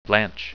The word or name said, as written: blanch